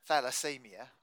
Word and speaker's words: thalassemia: Okay, but the aim was obviously your son, your oldest son, I think it is, had this thalassemia. I hope I'm saying that right. – Yes. – And yes, you are saying.